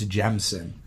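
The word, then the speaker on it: Jemisin: I always pronounce her last name wrong. I believe it's Jemison.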